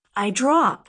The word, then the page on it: eye drop: American English